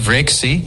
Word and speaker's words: βρέξει: Telaffuzu vreksi yani... – Evet. – Vreksi kısmında V yumuşak, R de hafif titrer.